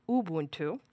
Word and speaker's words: Ubuntu: So when you go to .NET, one of the things I like about the experience is that, one, if you're on Ubuntu, sorry, I should correct it because otherwise Scott Hanselman will call me out. Ubuntu, that's the correct pronunciation.